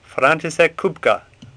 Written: Kupka

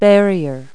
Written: barrier

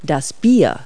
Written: beer

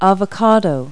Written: avocado

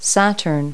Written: saturn

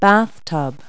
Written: bathtub

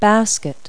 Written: basket

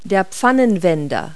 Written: turner

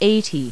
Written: eighty

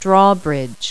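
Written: drawbridge

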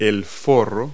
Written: sheath

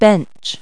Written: bench

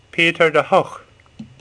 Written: Hooch

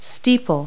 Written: steeple

